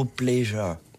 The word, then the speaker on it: pleasure: Je cite souvent le mot pleasure. – Ouais. – Pleasure. – Ouais. – Ça s'écrit P-L-E-A-S-U-R-E. Mm-hmm. Et pour un français, on a toujours tendance à faire pleasure ou finir un truc.